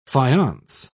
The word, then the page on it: faience: British English